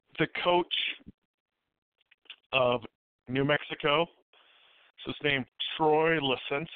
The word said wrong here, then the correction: Lesney: Is that his name? Is that how you pronounce it? – It's actually Troy Lesney, actually. Troy Lesney. – Lesney. Okay. I put an extra E in there and turned him into a Brazilian.